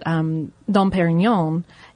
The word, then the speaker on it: Dom Pérignon: One of them is that Dom Pérignon, a monk, did I say that anywhere near correctly? You grimaced. – I did, but not because of your pronunciation.